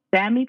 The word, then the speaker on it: Sami: He was, do you say Sami? Is it pronounced Sami? Sami people? – Sami.